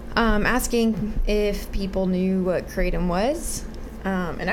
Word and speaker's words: kratom: The podcast is about Kratom, or Kratom. How do we call it? – So actually, I think the proper pronunciation, which I don't use, is Kratom.